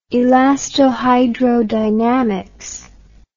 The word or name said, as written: elastohydrodynamics